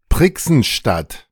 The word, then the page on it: Prichsenstadt: ˈpʁɪksn̩ˌʃtat; German